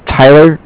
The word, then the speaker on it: Tyler: My name is Tyler, T-Y-L-E-R, and I'm with Bank of Cardiff. It's spelled C-A-R-D-I-F-F, as in Frank Frank.